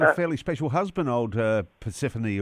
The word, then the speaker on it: Persephone: Is the name Persephone or Persephone or what is it? – No, well, very close. Persephone, I believe, is how it's pronounced.